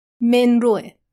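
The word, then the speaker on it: Monroe: اما روش صحیح گفتن نام فامیل ایشون منروه به کسر میم. این صدایی که میشنوید صدای گوگله منرو، و البته اسم کوچیکش به انواع و اقسام روش‌های مختلف تلفظ میشه.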